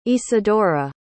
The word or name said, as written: isadora